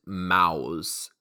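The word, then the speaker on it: Maus: It's pronounced Mouse, M-O-W-S, Mouse. It's like German.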